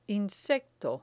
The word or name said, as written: insecto